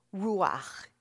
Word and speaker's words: Ruach: So this is all Ruach. It's spelled in English, R-U-A-C-H, pronounced Ruach. Try it. Okay. Gentiles don't always do the ch as well as Jews, but we Jews are a forgiving bunch.